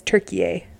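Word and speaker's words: Türkiye: It's Turkiye. T-U-R-K-I-Y-E.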